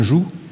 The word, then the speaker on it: joug: Vous savez ce que c'est tous un joug ? Un joug. Hein, on ne dit pas un joug, on ne prononce pas.